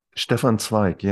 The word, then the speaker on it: Stefan Zweig: Stefan Zweig? – Stefan Zweig, yes.